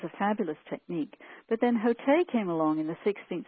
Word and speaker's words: Hotei: Hotei, that's spelled H-O-T-E-I, is a 16th Japanese mystic.